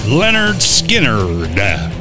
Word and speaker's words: Lynyrd Skynyrd: Celebrate the anniversary of the first album from Lynyrd Skynyrd, pronounced Lynyrd Skynyrd, released August 13th, 1973.